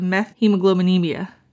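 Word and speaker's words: methemoglobinemia: All right, rural medicine, a mysterious case of meth hemoglobinemia.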